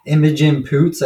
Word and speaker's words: Imogen Poots: It has Jesse Eisenberg and Imogen Poots. I think that's how you say her name. I don't know. – Yeah, you nailed it.